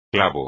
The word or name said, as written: clavo